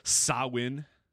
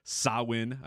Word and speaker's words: Samhain: I don't know that we can you know do the Sawin, even though it's spelled S A M H A N. It's Sawin. I, I, made sure to uh, learn what that how to pronounce it this week.